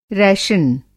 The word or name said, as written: रैशन